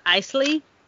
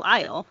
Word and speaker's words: Isle: It's I-S-L-E. – Yeah, it's Isle. – Okay, I was saying it's Isley for, three quarters of the book. And then I was like, I think it's Belle Isle in North Carolina.